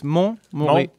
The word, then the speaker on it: Montmorillon: So it's Montmorillon.